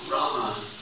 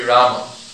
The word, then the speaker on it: Rama: In Sanskrit, the pronunciation must be Rama. In Hindi, you can say Ram. And even the Sanskrit people will clip that last A pretty short sometimes. Right? It's two syllables. Properly it's Rama. But you'll hear it clipped.